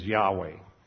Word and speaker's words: Yahweh: Now, because of that, there was question about how the tetragrammaton, the, those four consonants, are to be pronounced, but it's pretty well universally amongst the scholars now believed that the correct pronunciation is Yahweh.